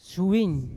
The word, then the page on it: syuwin: Yawa